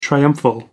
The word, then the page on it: triumphal: American English